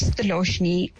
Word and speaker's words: Thiloshini: So my name's Thiloshni, and it is an Indian name spelled T-H-I-L-O-S-H-I-N-I. But the I at the end is silent, so people do often mispronounce my name. The common ones are Thiloshini and Thiloshni, and it even got, at a given time, Thiloshni.